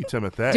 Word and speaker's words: Timothée: Yes, but his first name is spelled T-I-M-O-T-H-E. – Exante Goo. – Like, you know, Tim- Beyonce. – I mean, I don't know. – E, so it should be Timothée.